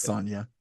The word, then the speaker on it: Sonja: Did you say Red Sonja? – Yeah, is that not how you say it? – Isn't it Red Sonja? – It's Sonja.